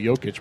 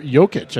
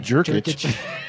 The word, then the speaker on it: Jokic: Who kept mispronouncing... – Jokic. – Jokic, I think. – Yeah. – Kept calling him Jerkic.